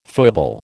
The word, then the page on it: foible: American English